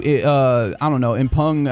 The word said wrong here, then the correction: impugn: I guess that's the right way to pronounce that word, which I don't remember. Impugn is the word I was looking for.